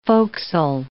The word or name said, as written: forecastle